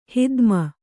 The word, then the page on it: ಹಿಧ್ಮೆ: Kannada